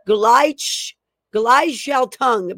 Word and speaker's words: Gleichschaltung: So when the Nazis rise to power in 1933, the new regime immediately began trying to transform German society in a process known as Gleichschaltung, basically is what how it's pronounced, which is German for coordination or synchronization.